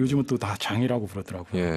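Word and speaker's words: Jean: 위대한 힙합 블랙뮤직 그룹으로는 영원히 뭐 매번 언급될 수밖에 없는 그런 그룹이기도 하고 이 와이클리프 진은 거기에서 아 제가 와이클리프 진이라고 하는 이유 또 어릴 때 처음 들었을 때는 맞아요.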